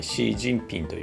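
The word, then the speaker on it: Xi Jinping: 英語ではあの中国語発音に近いシー・ジンピンという呼び方をしましてその C が XI と書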